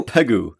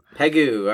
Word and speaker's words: Pegu: Pegu? It looks like ragu. Let's go Pegu. – Pegu.